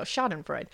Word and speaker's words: Schadenfreude: I don't Schadenfreude. – Know. Schadenfreude. There you go. Schadenfreude.